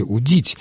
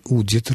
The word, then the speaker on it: удит: Конечно, царь удит рыбу, а не удит. Хотя в неопределенной форме «удить» ударение на суффиксе после корня, в личных формах этого глагола ударение на корне «удит».